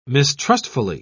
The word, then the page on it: mistrustfully: British English